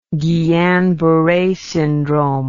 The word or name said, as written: Guillain-Barre syndrome